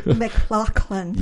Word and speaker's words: McLaughlin: McLaughlin.